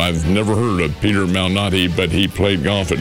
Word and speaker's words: Malnati: Let's see, he pronounces it Malnati.